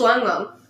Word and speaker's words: ylang: I think it's it's probably just lang lang. – Lang lang, yeah. – I don't know. – Neroli. – It's spelled Y-L-A-N-G.